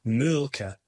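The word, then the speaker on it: Mölkky: Most of the people we play against use Malky, but the Finns have a very unusual pronunciation, which, please forgive me, I'll try it. It's Mulka.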